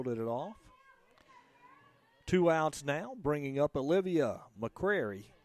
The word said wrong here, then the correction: McCray: And I've just been informed that I'm mispronouncing Olivia McCray. It's not McCrary, it is McCray.